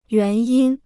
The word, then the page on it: 原因: yuán yīn